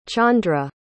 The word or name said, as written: chandra